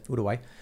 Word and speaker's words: Uruguay: In South America, in Uruguay, for instance, or Uruguay, as they pronounce it, Uruguay, they say sha instead of ya.